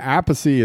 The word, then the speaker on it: Appice: but Carmine says it's Appice. He just pronounces it Appice because he wanted to separate himself.